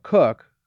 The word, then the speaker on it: Cooke: Sam Cooke, whose last name also has a silent E, but his deeply religious father was not particularly tolerant in that way.